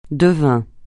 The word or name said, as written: devin